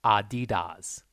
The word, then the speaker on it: Adidas: How do you pronounce Adidas? And it says it's pronounced Adidas. – Adidas. – Adidas. Adidas, yeah. And well, that's the American way is Adidas.